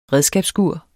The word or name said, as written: redskabsskur